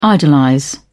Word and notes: idolize: British English

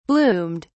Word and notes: bloomed: British English

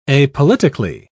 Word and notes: apolitically: British English

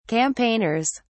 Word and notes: campaigners: British English

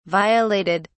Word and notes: violated: British English

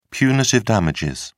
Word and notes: punitive damages: British English

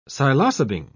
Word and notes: psilocybin: British English

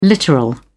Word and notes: literal: British English

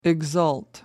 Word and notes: exult: American English

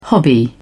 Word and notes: hobby: British English

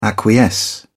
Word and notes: acquiesce: British English

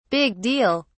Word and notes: big deal: British English

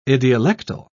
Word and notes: idiolectal: British English

